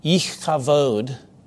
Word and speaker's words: Ichabod: And she dies bearing the child, but before she dies, she names the child Ichabod, or more accurately pronounced in the Hebrew, Ichavod.